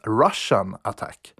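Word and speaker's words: Rush'n Attack: Arkadets originalnamn var Green Beret, men nästspelet fick den underfundiga titeln som ska uttalas Russian Attack.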